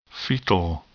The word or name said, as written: fetal